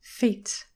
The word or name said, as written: feet